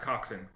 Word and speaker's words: coxswain: It's coxswain. It's pronounced coxswain, but it's literally... COXSWAIN.